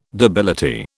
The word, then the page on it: debility: British English